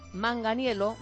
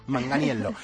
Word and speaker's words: Manganiello: Bueno, este era fácil, que solo la doble L... Se pronuncia como una L. Haces así como Manganiello. – Como si lo pusiésemos en negrita, ¿no? – Eso. – Manganiello.